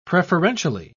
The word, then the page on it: preferentially: British English